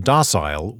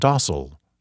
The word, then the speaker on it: docile: Off the top of my head, I've been saying docile when it should be pronounced docile.